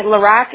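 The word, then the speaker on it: LaRoque: It will be LaRock, R-O-C-K, right? – Oh, no. My LaRock is spelled L-A. Capital R-O-Q-U-E.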